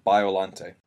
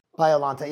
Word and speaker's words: Biollante: How do you pronounce it? – In English, it would be translated to Biollante. – Biollante, yeah.